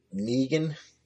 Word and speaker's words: Negan: I mean, it rhymes with Egan. – Negan.